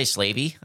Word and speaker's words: Slavey: Let's talk about the slavey. I think that's how it's pronounced. It's S-L-A-V-E, which is slave, right? – Yeah. – Why? – I would say slavey.